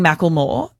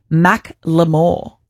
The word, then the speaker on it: Macklemore: So I've been saying Macklemore, and apparently a lot of the world has as well, but it's actually Macklemore.